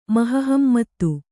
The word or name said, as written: ಮಹಹಮ್ಮತ್ತು